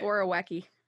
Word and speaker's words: Borowiecki: Borowiecki.